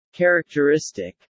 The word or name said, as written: characteristic